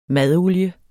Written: madolie

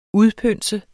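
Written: UDPØNSE

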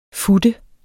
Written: fut

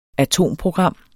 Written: atomprogram

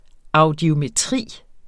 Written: audiometri

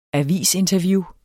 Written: avisinterview

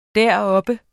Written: deroppe

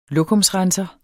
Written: lokumsrenser

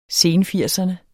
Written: senfirserne

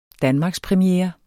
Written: danmarkspremiere

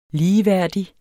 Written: ligeværdig

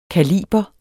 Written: kaliber

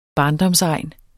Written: barndomsegn

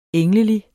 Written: englelig

